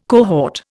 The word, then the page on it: cohort: Indian